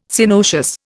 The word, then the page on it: Synoecious: Indian